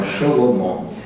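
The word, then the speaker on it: Шелумо (שְׁלֹמֹה): Ну, в любом случае, конечно, это условность, можно было произносить по-современному, но вот мы так, читаем Шелумо.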